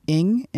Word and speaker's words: Ng: The president, the senior vice president of baseball operations for the entire Major League Baseball, Kim Ng, N-G. I found out how to pronounce that. Ng. – What? Ng? – Yeah, it's N-G, but it's pronounced E-N-G. Ng.